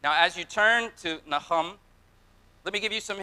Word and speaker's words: Nahum: Now, the ancients would have pronounced it more like Nahum, but, you know, we say Nahum, so turn to Nahum.